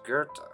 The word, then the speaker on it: Goethe: It's actually pronounced Goethe.